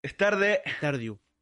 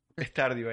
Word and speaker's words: Stardew: Stardew. Stardew.